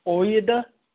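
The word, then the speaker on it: Ouidah: The Kingdom of Judah was actually, they had a different pronunciation. It was Oyedah, which was spelled O-U-I-D-A or something like that.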